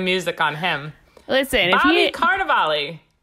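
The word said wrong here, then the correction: Cannavale: So I know how to pronounce his last name. It's Canav. I have no idea how to say it, but there's no R. – Canavale? – Canavale.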